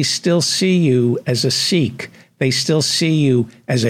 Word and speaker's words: Sikh: It's pronounced Sikh in among the Punjabis, so but we'll call you Sikh for the American audience.